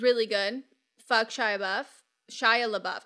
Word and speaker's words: Shia LaBeouf: I can't say his name because for the longest time I thought it was Shyla. And then I thought it was Shia LaBeouf. And so first name Shyla, last name Buff.